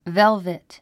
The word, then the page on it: velvet: vélvit; ベルベト